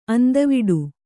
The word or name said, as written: ಅಂದವಿಡು